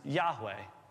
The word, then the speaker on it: Yahweh: But you can't process this word with no vowels, and so this YHWH is pronounced Yahweh. We think, at least. Because we don't actually know what vowels fit in there. Most people agree that it's Yahweh, but the reason we don't know is because those vowels were never written down.